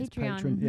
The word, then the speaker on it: Patreon: Patreon. – It's Patreon.